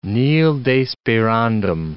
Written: nil desperandum

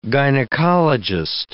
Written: gynecologist

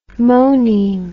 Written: moneme

